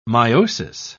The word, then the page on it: myosis: British English